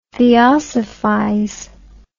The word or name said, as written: theosophize